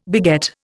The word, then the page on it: beget: Indian